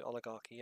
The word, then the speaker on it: oligarchy: Oligarchy. It's CH as a K, isn't it? So oligarchy, yeah.